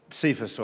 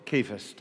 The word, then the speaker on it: Cephas: The thing you need to know is that Simon, who's called Peter, is also called Cephas or Kephas, depending on how you pronounce it.